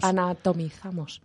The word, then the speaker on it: anatomizamos: ¿Y ahora quién anotonizamos? ¿Anotizamos? ¿Cómo se dice? ¿Anatonizamos? – Anatomizamos.